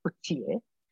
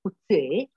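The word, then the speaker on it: Coetzee: Există și pronunția cuție, însă autorul își pronunță numele cuției, așa cum se pronunță acest nume, care este un nume special și se găsește, uh, e frecvent în Africa de Sud.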